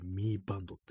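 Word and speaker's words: Mi Band: なんかあの、シャオミとかの、ね、なんかミーバンド、 MI って書いてミーバンドって読むらしいんですけども、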